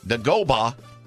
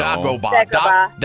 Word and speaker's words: Dagobah: Luke goes to train with him on the planet Dagobah. Did I say that right? – No. – Dagobah.